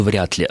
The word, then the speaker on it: вряд ли: Все-таки это слово нужно произносить с глухим «т» на конце, хотя формально там идет звонкий согласный «л», и вроде бы должно происходить озвончение, но все орфоэпические словари рекомендуют как правильную форму «вряд ли» на «вряд ли».